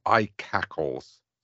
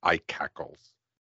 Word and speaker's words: ICACLS: And I think I've heard somebody from Microsoft way, way back when mentioned this as iCackles.